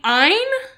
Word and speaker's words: Ayn: You say Ayn, which is apparently, I think it's a Hebrew word for I. – Okay. – And Rand.